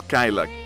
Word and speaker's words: Kyla: Siempre se me olvida decir la pronunciación es Kyla. K-Y-L-A. Porque Kyla sonaría como killer. Como dicen otras personas, lo pronuncian Kyla. No, este es Kyla.